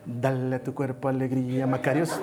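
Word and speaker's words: Macarios (μακάριος): Esta palabra bendecido dedo una palabra que se pronuncia Macarios. A ver, di conmigo, Macarios.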